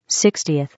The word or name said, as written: 60th